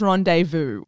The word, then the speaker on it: rendezvous: So before anyone comes into the group to tell me I've mispronounced something, I know it's rendezvous.